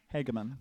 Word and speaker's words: Hageman: My name's Hans Hageman. – Yes. Thank you. Wait, is that how you say your last name? – Hageman. – Oh, I thought it was always Hageman. – No, a long A.